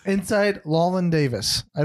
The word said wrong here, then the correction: Llewyn: I think it's just Lewin Davis. L-L-E-W-Y-N.